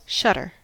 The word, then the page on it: shutter: American English